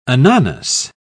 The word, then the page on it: ananas: British English